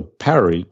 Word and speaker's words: Powerage: The Power Age? Power Age? Yeah. – I've heard them say like, I don't know how they say Powerage. – Powerage.